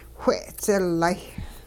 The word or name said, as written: whe'dzilay